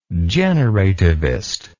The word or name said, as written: generativist